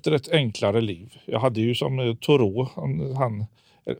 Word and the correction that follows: Thoreau: förlåt, eh, Thoreau. – Mm. Jag försöker vänja mig att han var amerikan och inte ja. fransman, så jag, det är uttalet där.